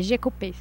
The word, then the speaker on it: Jecupé: E elas também trouxeram uma sonora aqui pra gente do Olívio Jecupé, essa é a pronúncia correta? – É, Jecupé, se eu não me engano.